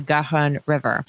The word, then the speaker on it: Garonne: He was a young engineer who had come to the city as a construction chief, building an iron railway bridge across the Garonne River.